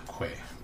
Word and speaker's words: Quay: Quay. – It's probably pronounced Key.